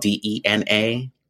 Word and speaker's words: Dena: Um, name is spelled D E N A.